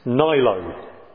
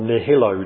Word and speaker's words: nihilo: Or if you want to use a fancy phrase that is beloved of some theologians, creatio ex nihilo, or nihilo, depending on how you wish to pronounce the Latin word.